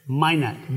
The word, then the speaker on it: Minot: Minot.